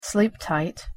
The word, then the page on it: sleep tight: English